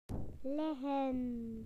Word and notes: lie down: British English

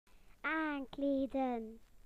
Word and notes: to dress: British English

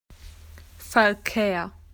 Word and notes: traffic: Australian English

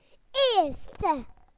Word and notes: first: British English

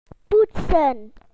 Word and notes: to clean: British English

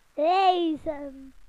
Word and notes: to travel: British English

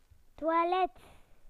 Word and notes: toilet: British English

